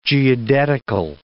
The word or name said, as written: geodetical